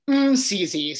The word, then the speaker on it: Masisi: Is it Masisi? – It's Masisi. So it's just like the and then Masisi. Masisi.